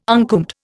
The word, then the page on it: uncombed: Indian